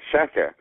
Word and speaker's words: Shaker: And my most recently established campaign, which is We Stand With Shaka, for Shaka Armour, which is spelled Shaker, but Shaka Armour is the last British resident in Guantanamo.